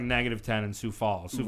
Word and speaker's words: Sioux Falls: And for the longest, I thought it was called Swix. Because it's spelled very. I'm like, yo, why don't you just do S-U-E, my guys? But it's Sioux Falls, not Swix.